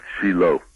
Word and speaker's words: Shiloh: And is it Shiloh? Did I pronounce that right, David? – Oh, you pronounced it right if you're talking in America. – Right, okay, fine. How would you pronounce it over there in Israel? – In Israel, we call it Shiloh.